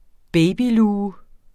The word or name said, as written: babyluge